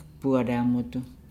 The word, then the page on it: bua laangudu: Nukuoro